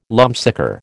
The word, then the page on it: lumpsucker: American English